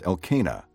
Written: Elkanah

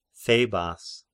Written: Thebas

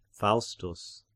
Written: Faustus

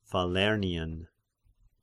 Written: Falernian

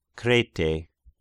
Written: Krete

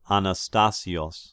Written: Anastasios

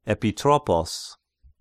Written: Epitropos

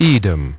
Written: Edom

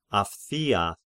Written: Aphthia

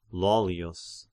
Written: Lollius

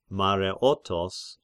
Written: Mareotos

